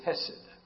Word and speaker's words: chesed: The word in the Hebrew is hesed. Well, it's actually spelled C-H-E-S-E-D, with the C is silent here, and it becomes a deep sound, but hesed, for lack of a better term.